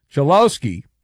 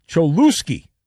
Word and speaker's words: Cholowski: Instead of correctly pronouncing the player's name, Chelowski, which is his name, Manfred pronounced his name Cholowski.